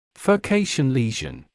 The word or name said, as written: furcation lesion